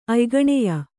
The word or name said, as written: ಅಯ್ಗಣೆಯ